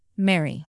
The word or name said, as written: merry